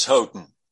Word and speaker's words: Houghton: I like to say Houghton Mifflin, and I don't want to change that. Is it Houghton Mifflin or Houghton Mifflin? – It's Houghton.